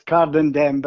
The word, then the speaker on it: Cardenden: Cordonder, is that how you pronounce the area that you were born? – I was born, strangely enough, it comes under Cardenden. – Got ya. – It's Cardenden, but I was actually born in Dundonald, which is sort of a little village.